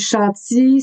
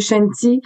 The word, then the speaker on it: Chanty: Euh, non plus, en fait, euh, donc les gens me, disent, me demandent toujours la première question, comment on prononce, c'est-tu Chanty, c'est-tu Chanty? Euh, c'est un peu des deux, en fait, c'est un diminutif de Chantal. ma mère m'appelait Chanty quand j'étais jeune et moi, j'ai fait beaucoup de danse euh, orientale, baladie, euh, etc.